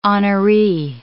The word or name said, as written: honoree